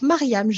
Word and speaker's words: Maryam: Alors, euh, déjà, on peut me retrouver euh, sur Instagram, Mariam Accompagnante. Donc, Mariam, j'épelle parce que tout le monde écorche mon prénom. – Mariam, M-A-R-Y-A-M, comme Marie.